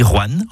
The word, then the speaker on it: Rouen: Vous avez remarqué comment je dis Rouen en une syllabe plutôt que Roanne en 2 syllabes ? C'est comme ça qu'on y dit dans la région.